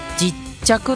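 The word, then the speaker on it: 勢理客: 勢いがある勢いという字と理科の理とお客様の客と書いて整理客と書いて実着と読むんですよね。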